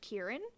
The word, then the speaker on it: Ciarán: This episode was directed by, I'm sorry, I think I pronounced his name Ciarán last episode. It's pronounced Ciarán Donnelly, and written by Rafe Judkins.